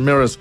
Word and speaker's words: Mraz: Pero es Jason M-R-A-Z, pero se pronuncia Jason Mears, el invitado en el especial musical ahí del Trasnoche.